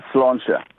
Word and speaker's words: Sláinte: Well, the Irish, Sláinte.